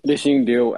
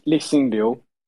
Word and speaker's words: Lixin Liu: We have got Lixin Lu. That's how you say your last name, by the way, right? – That's Lixin Liu, actually.